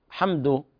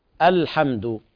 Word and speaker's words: الحمد: هناك البعض ينطقها لا ينطق حرف الألف ويختلس الحركة اختلاسا، تظن أنه يقرأ حمد بدون ألف واللام، وهذا خطأ، لابد من تحقيق الحركة. الحمد.